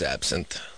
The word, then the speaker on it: absinthe: How do you say that? Absinthe? – Yeah, it's absinthe.